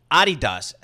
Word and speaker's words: Adidas: Adidas, as we say it in Europe. – Okay, yes. Matt. Named for Adi Dassler. Thank you very much for pronouncing it correctly.